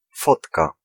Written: photo

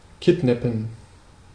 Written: abduct

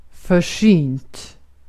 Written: försynt

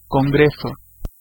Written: meeting

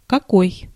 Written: wat

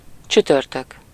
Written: Thursday

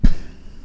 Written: betoning

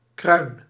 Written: tête

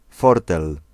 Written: deceit

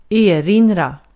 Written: erinra

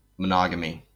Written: monogamy

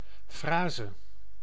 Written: uttryck

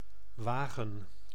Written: wagen

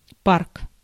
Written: depot

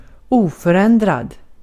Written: unchanging

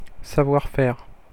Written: savoir-faire

